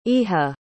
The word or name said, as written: Iha